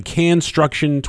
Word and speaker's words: Canstruction: Canstruction 2015, kind of like construction, but with cans of food. – That's correct.